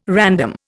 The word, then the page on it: random: Indian